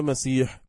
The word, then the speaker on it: مسيح: والمسيح كلمة عبرانية بمعنى الوصف، ونقلت إلى العربية علما بالغلبة على عيسى، وقد سمى متنصرة العرب بعض أبنائهم عبد المسيح، وأصلها مسيح بميم مفتوحة ثم سين مهملة مكسورة مشددة ثم ياء مثناة مكسورة مشددة ثم حاء مهملة ساكنة، ونطق به بعض العرب بوزن سكين. ومعنى مسيح